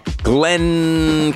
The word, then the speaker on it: Glenn: Glenn Camaro. Gotta pronounce that second N in Glenn.